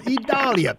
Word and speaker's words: Idalia: Idalia, I am told, is the proper pronunciation for this storm.